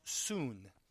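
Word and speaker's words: soon (σύν): That word in the Greek is spelled S-U-N like our son, only it's pronounced soon.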